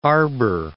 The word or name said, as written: Arber